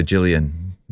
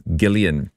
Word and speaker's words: Gillian: Right, I used to call her Gillian, but you fans know that she's Gillian.